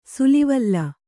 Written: ಸಾಲ್ವಲೆ

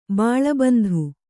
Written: ಬಾಲಬಂದು

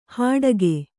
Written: ಹಡಕೆ

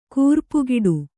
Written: ಕೂರ್ಪುಗಿಡು

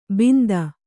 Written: ಬಿಂದ

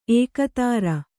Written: ಏಕೋದರ

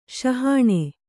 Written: ಸಹನಾ

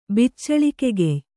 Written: ಬಿಚ್ಚಳಿಕೆಗೆಯ್

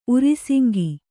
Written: ಊರುಸಿಂಗ

